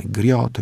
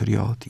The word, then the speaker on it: griot: Griot, riot i w Swahili, i w Ibo, i w Yoruba, i w Hausa. Mniej więcej tak jest wymawiane.